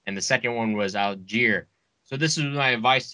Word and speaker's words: Allgeier: the rookie Tyler Allgeier, and yes, that's how you say it, motherfuckers.